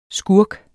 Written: skurk

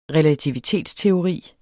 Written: RELATIVITETSTEORI

